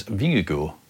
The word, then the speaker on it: Vingegaard: How do you say it? – Jonas Vingegaard. – There you go. – I think that's about right.